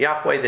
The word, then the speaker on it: Yahweh: The Jewish Encyclopedia, Jehovah, a mispronunciation of the Hebrew Yahweh, the name of Elohim, which again, they're saying G-O-D. The pronunciation of Jehovah is grammatically impossible.